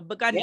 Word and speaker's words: Piikani: So I was taught that from a Piikani member that it's like a B, bzz, gun, and then slap your knee. So Piikani, yeah.